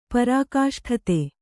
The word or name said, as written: ಪರಾಕಾಷ್ಠತೆ